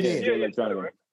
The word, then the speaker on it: Jay Electronica: I don't really know Jay Electronica. Did I say it right? – Yeah, Jay Electronica.